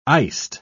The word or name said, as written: iced